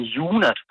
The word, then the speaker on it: Hyuna: Det er navnet på dansk, siger man Jonas, fordi at H udtalt stumt. Men det er H-Y-U-N-A.